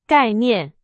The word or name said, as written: 概念